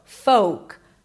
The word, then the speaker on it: folk: It is not folk. There is no L and the O is a long O as in go. Folk.